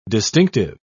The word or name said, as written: distinctive